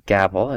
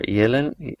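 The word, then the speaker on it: Gävle: I'm going to say Gavel is how you pronounce it, but it might be the Scandinavian, Swedish, you might have to say Gavel. – Sure. – Sure, yeah. Or Jelen.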